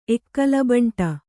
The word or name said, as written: ಎಕ್ಕಲಬಂಟ